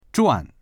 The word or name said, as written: zhuan4